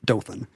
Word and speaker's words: Dothan: We wondered how to pronounce Dothan, Alabama, and he was one of several people who replied that we got it right. It is Dothan.